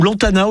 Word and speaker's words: Lantana: Les Lantanas, ou Lantana aujourd'hui, comment vous le prononcez vous? – Moi je prononce pas le S. – Ah, bon les Lantanas. – Je dis Lantana, voilà.